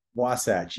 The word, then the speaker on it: Wasatch: If he's not teaching, writing, or working with patients in office or virtually, you will find in the, I think I'm saying this right, Wasatch Mountains with his wife, Jess. What was it? – Wasatch. You got it.